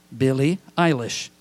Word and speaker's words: Billie Eilish: Her name is pronounced Billie Eilish.